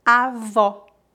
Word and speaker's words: avó: E o O aberto pronuncia-se como a letra O na palavra orange em inglês. Orange, avó.